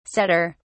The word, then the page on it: sadder: British English